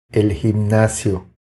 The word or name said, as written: El gimnasio